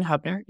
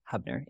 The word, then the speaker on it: Hubner: It's at Meghan Hubner, H-U-B-N-E-R is my last name. My first name is spelled with an H, so it's M-E-G-H-A-N, Hubner, H-U-B-N-E-R.